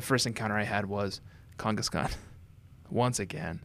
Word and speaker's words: Kangaskhan: Is that, am I saying it right now, Josh? You want me to elongate the A's? – Yeah, you got it. Kangaskhan. – Kangaskhan.